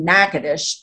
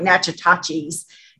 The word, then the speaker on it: Natchitoches: I was born in Alexandria in the middle of the state, but I grew up most of my teenage years in Natchitoches. And yes, that's how you say it. I know it looks like Natchitoches, Natchitoches, Louisiana.